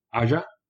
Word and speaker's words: Aja: I mean, the title is just perfect because Alexander Aja, or however you say it, Aja. Yeah. Say it one more time. – Aja. – Okay, Aja. Thank you. – That's how I would pronounce it.